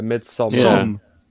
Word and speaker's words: Midsommar: Midsommar. – Sommar. – It's probably the Swedish spelling. – I don't know, because D from Twitter, you know, DeJera, he was saying don't pronounce the D. So it's Midsommar.